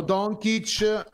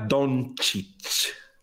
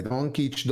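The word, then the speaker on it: Dončić: Allora, c'è però una cosa, bisogna che insegniamo come dire, come pronunciare correttamente il cognome di Luca. Potresti, allora, Luca Doncic. – Vabbè, Doncic. – No, Doncic. – Doncic, prendiamo Doncic ed è fatta. – Applauso! Per la prima volta da 7 mesi a questa parte ha detto Doncic e non Doncic.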